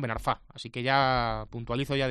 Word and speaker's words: Ben Arfa: No es Ben Arfa, sino Ben Arfa, con acento final en la A, es palabra aguda.